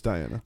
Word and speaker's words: Diana: Did I say Deanna? Or is it Diana or Deanna? – It's Diana.